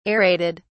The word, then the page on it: aerated: British English